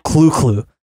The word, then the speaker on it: Cthulhu: Remember, Lovecraft himself is said to have pronounced Cthulhu as the guttural clu-clu, while the more common ones, Rulie Fatang, tend to come out more mangled.